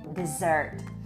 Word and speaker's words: dessert: No i tutaj jest właśnie te przejście tego akcentu, że wyraz pustynia akcentujemy na pierwszą sylabę, wymawiamy desert, a wyraz deser wymawiamy z akcentem na drugą sylabę, czyli dessert.